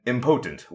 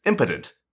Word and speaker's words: impotent: It's impotent, not impotent. And that one annoys me, because potent is an English word, and the M modifier is like an English prefix, like impossible, something that's not possible is impossible, right? So, like, in my head, impotent works. And I think that makes sense, but it's actually pronounced impotent, and that's dumb.